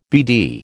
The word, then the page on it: BD: American English